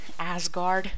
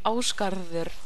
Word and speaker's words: Ásgarður: Like Asgard, what the fuck? It should be Ásgarður and not some freaking name for a porn film.